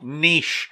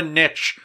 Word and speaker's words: niche: Why can people not pronounce the word properly? – It's like niche. It's called niche. It's not a niche. Learn the freaking language.